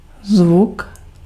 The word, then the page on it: sound: /saʊnd/